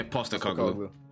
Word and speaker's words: Postecoglou: How do you say their manager's name? Postecoglou? – Yeah, Postecoglou.